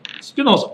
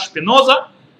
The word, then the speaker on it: Спиноза: Барух Шпиноза. А? Спиноза, правильно. На иврите он, его имя Барух Шпиноза, э, который жил в 1632 год и до 1677.